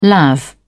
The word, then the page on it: lav: British English